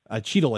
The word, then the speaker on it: Chytil: but am I saying his name right? – Chytil, I believe. – Chytil, sorry. Yes.